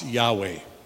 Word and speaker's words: Yahweh: the people of Israel had known God by the special name YHWH, which we pronounce Yahweh.